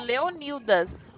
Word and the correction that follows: Leônidas: Não, é Leônidas. A pronúncia é Leônidas.